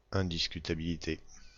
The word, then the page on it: indiscutabilité: France; /ɛ̃.dis.ky.ta.bi.li.te/